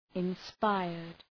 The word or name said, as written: inspired